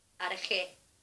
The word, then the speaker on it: arché: Poder en griego se dice arjé, que se escribe arché.